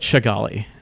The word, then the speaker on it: Kigali: But in Kinyarwanda, which is the Rwandan language, the way you pronounce the K-I-G-I-L-A, Kigali, the way you pronounce the K sound is like a ch, like a ch. – It's more like Chigali? – So it's really more like Chigali.